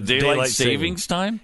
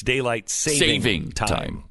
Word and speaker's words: daylight saving time: And it's actually, we're both saying it wrong. It's daylight saving time. – Yes. – There's no S in savings. – Right. – Leave the S off.